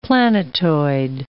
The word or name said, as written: planetoid